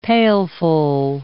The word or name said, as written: pailful